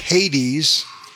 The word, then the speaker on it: Hades: Hades, pronounced in English Hades.